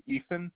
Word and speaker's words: Ethan: It's going to be Ethan, E-T-H-A-N.